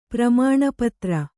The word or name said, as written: ಪ್ರಮಾಣಪತ್ರ